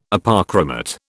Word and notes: Apochromat: British English

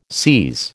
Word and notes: seise: American English

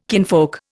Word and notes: kinfolk: Indian

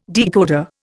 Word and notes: decoder: Indian

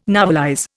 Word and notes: novelize: Indian

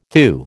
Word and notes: thew: American English

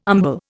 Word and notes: umbel: Indian

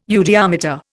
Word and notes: eudiometer: Indian

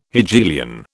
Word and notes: Hegelian: British English

